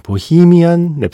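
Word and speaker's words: Bohemian: 동영상 사이트에 웨인즈 월드 W A Y N E 어퍼스트로피 S 월드 보헤미안 랩소디 물론 원래 발음을 하면 보히미안 랩소디가 되겠지만요.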